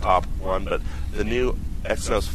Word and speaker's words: Exynos: Their Exynos. – Exynos? – Yeah.